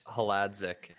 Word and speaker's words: Hladczuk: Haladzic.